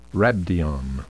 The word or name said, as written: rhabdion